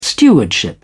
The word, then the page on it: stewardship: British English